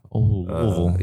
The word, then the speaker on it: Louisville: I was born in Louisville, Kentucky, but Louisville. – Yeah. – That's really how you say it too. It's not Louisville.